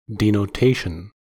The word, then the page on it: denotation: American English